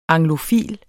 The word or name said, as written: anglofil